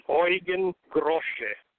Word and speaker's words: Eugen Grosche: Well, after Gregor Gregorius, who Eugene Grouch, how do you pronounce his last name? – Eugen Grosche.